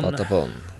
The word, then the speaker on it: Patapon: Men i hvert fall så kommer det Parappa the Rapper, og så kommer Patapon. Er det riktig uttalt? – Ja. – Patapon.